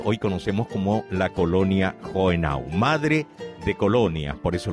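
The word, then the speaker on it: Hohenau: Ustedes saben que Joenau, o Jogenau, como dice que se pronuncia, por lo menos eso me dijeron desde Alemania, que ellos conformaron después el resto de las colonias que conforman hoy las Colonias Unidas.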